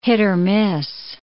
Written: hit-or-miss

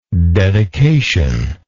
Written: dedicational

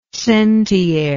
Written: centiare

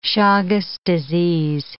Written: Chagas' disease

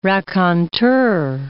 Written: raconteur

